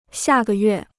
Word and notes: 下个月: xià gè yuè

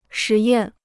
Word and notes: 实验: shí yàn

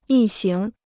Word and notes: 一行: yī xíng

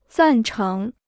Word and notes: 赞成: zàn chéng

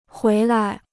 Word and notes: 回来: huí lai

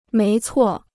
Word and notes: 没错: méi cuò

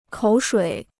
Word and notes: 口水: kǒu shuǐ

